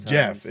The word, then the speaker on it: Geoff: His name's Jeff. It just spelled like that.